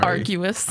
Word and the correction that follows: arduous: Arguous. Arduous. – We've been arguing a lot. Yeah. Just kidding. – It's been arduous. Right. – Arduous. Yes.